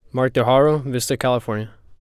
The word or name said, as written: Mark De Haro